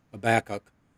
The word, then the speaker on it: Habakkuk: And we're going to start out with going back into the Old Testament, the book of Habakkuk.